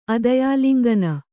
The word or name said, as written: ಅದಯಾಲಿಂಗನ